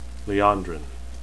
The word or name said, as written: liandrin